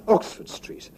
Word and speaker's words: Oxford Street: Kaj mi devas diri al vi, ke la reguloj de la angla lingvo postulas, ke vi akcentu road, Oxford Road, sed ke vi akcentu Oxford en Oxford Street. Ĉar tiuj du vortoj, pli malpli sinonimaj, tamen sekvas malsamajn principojn de akcentado.